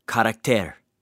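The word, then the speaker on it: charakter (χαρακτήρ): The word, or the phrase "express image" in the Greek is pronounced this way, charakter. It's the very word that we get for the word character.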